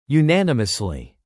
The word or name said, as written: unanimously